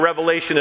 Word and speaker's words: Revelation: And remember, Revelation is pronounced singular, Revelation.